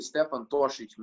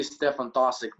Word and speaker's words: Stefan Tosic: It's actually Stefan Tosic, man, but it would be Stefan Tosic maybe in some sort of an American slang or whatever.